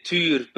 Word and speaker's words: Tuur: Yeah, I say Tuur, but that's pretty unpronounceable for most people in the world.